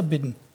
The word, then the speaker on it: Biden: Heißt der Biden oder Bidden? Biden würde ich aussprechen, oder? – Ja. – Egal. – Ich höre immer nur Biden.